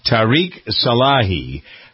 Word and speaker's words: Tariq Salahi: This is a 2020 name quiz looking for the identity of Tariq Salahi. Did I pronounce it right that way? – That's right. – Tariq Salahi.